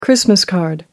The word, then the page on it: christmas card: American English